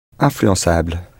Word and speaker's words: influençable: Influençable, spelt I-N-F-L-U-E-N-C-Cedilla-A-B-L-E, with the C cedilla, because to keep a soft S sound, you need to put a cedilla on a C before A, O or U. Otherwise, it would be influencable.